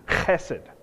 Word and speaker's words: hesed: It's the word hesed. Hesed. If I say it right in Hebrew, it's hesed.